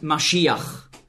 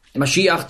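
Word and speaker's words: Maschiach (משיח): In meiner Bibel steht Messias, weil im Grundtext hier das Wort Maschiach, so ist die hebräische Aussprache, zu finden ist. Messias ist eine sprachliche Anpassung ans Griechische. Nicht wahr? Im Griechischen konnte man das den Sch-Laut nicht aussprechen. Und da war es also nicht möglich für Griechen, einfach so Maschiach zu sagen und schon gar nicht zu schreiben, weil im Griechischen gibt es keinen Sch-Laut.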